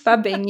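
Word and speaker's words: phubbing: Let me see if I'm saying it right. Fubbing? It's snubbing and phone. Tell us about this. – Fubbing, yes.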